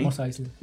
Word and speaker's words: Mos Eisley: Mos Eisley.